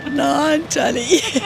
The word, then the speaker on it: Naantali: We're going on that trip that goes to, I'm not sure how to pronounce it, Naantali. Sorry. – But I got it. – Yeah, you got it right. Naantali. – Do you know the Moomins live in Naantali? – No.